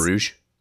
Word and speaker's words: Bruges: In Bruges.